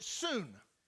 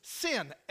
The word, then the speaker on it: syn: And its synonym is, the prefix is S-Y-N. And in Greek, you pronounce it soon. But in English, the transliteration is sin, S-Y-N.